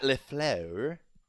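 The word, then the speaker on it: LaFleur: Matt LeFleur. That's how I pronounce it, if you want the correct French-based pronunciation.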